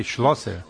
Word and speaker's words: Schlosser: Es una profesión en alemán. Es cerrajero. – Cerrajero. – Sí, Schlosser.